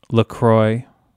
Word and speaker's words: LaCroix: There's just, you know, LaCroix. As it's properly pronounced.